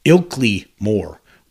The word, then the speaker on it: Ilkley Moor: Keep pronouncing that wrong. It's Ilkley Moor, where he then took the famous photograph.